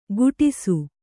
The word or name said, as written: ಕುಡಿಸು